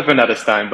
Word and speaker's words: Vanidestine: It's Dakota Vanidestine, but no worries at all.